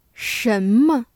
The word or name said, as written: 什么